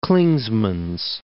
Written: Clingmans Dome

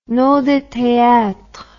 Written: nom de théâtre

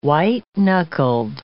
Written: white-knuckled

